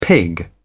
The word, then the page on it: pig: British English